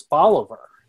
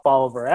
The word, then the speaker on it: Bolivar: You go, no, it's Bolivar. And it definitely is down here. I live on South Bolivar Avenue, and I'm in Bolivar County, and that is the way it's pronounced in the Mississippi Delta.